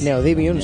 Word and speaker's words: Neodymium: Neodymium, creo que es. – Neodymium, sí, bien.